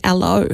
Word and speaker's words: Allo: But if you don't fancy Hangouts, they have a simpler chat app called Allo.